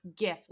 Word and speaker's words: GIF: Obama took a side in 2014, announcing his official position is that it is pronounced GIF, like a hard G, like a grape.